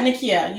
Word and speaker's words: Nickia: Yeah, Nickia, you said it.